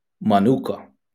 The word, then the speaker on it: manuka: The Hebrew word for rest is manuka. Man, now I know I butchered this. M-A-N-U-K-A. – That's right.